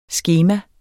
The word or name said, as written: skema